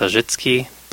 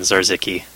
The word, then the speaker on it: Zarzecki: The way Polish people say it is Zarzecki, but the default Canadian standard pronunciation seems to have settled on Zarzecki, so yeah. I'm happy using that.